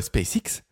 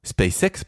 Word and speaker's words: SpaceX: Et du coup, Musk s'est dit, mais si moi, avec euh, SpaceX, SpaceX, pardon, sur la prononce américaine, je faisais ratterrir mes fusées.